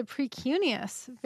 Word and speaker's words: precuneus: Tell me if I'm pronouncing this correctly. The precuneus? – Yes, the precuneus. Very nice.